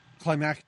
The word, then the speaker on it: climactic: But watching it in a movie as the end scene, it wasn't climatic enough. Climatic? – Climactic. – Climactic. – You got to put some syllable in there.